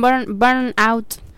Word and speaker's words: burnout: Hoy vamos a hablar del burnout. No sé si lo estoy pronunciando bien, profesora de inglés, dígame. – Sí, está bien, burnout.